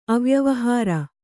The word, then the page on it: ಅವ್ಯವಹಾರ: avyavahāra